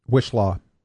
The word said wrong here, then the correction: Whishaw: I think. Wishlaw. Oh, no, Wishaw. Okay. Yeah, I think you had it right. Wishaw.